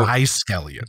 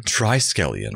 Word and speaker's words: Triskelion: So, from the title alone, I had forgotten what this episode was like, but it's called The Gamesters of Triskelion. And I apologize if I'm saying that wrong. – So, Triskelion. – Triskelion.